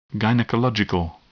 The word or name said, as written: gynecological